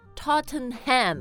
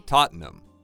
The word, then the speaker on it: Tottenham: Because the spelling, you want to say Tottenham. – Right. – But actually they say Tottenham. – Tottenham.